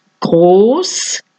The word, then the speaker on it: Groß: La beta, que se llama en alemán es esta letra rara, se pronuncia como una S fuerte. Groß, heißen, schließen, Fußboden, Pass.